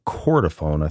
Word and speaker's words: chordophone: which he referred to throughout as a chordophone. I think I'm pronouncing that right. – You are.